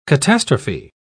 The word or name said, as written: catastrophe